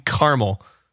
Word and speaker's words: Carmel: Here in California, there's a city called Carmel, and it's spelled C-A-R-M-E-L, Carmel, and so I feel kind of bad not saying caramel.